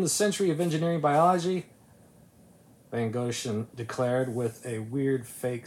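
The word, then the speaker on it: Wegrzyn: I really had to look it up because it's spelled W-E-G-R-Z-Y. – German. – Yeah. – Yeah. – It's pronounced Van Goshen.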